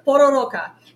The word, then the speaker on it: Pororoca: Yeah, you still Pororoca. And am I saying that right, Daniel? Or am I just butchering it? If I'm butchering it, I apologize. Because again, I do not speak Portuguese. The most I can do is a pathetic attempt to try. Oh, yeah. But these, okay, and Daniel says, good pronunciation.